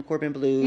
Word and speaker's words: Corbin Bleu: And Cordon Bleu, right? – Yeah, yeah, him, Corbin Bleu. – Yeah, him. I said Cordon Bleu. – Not Cordon. Not the restaurant with me.